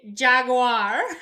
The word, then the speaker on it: Jaguar: Is it Jaguar or is it Jaguar? – I say Jaguar, but I think some people do say Jaguar. – You say Jaguar, like J-A-G-W-I-R-E. – Yes, I say Jaguar.